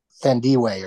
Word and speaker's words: Thandiwe: Who now has an extra, the spelling slightly different, is T-H-A-N-I or D-I-W-E. There's a W in there now, and it's pronounced differently, and I don't want to screw it up, but Thandiwe or something like that.